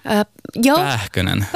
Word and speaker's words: Pähkönen: Och jag tänkte att vi måste prata om skådespelaren Jasper Pähkönen. Uttalar jag namnet rätt? – Pähkönen.